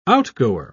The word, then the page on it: outgoer: British English